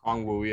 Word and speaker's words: Okongwu: Okongwu. Am I saying it correctly? – Okongwu, yeah.